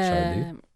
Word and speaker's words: Sade: En plus, c'était chanté par une femme, celle-là, Shardy. – Oui, effectivement, Shardy. Merci. – Ça s'écrit S-A-D-E, mais ça se prononce Shardy.